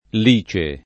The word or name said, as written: lice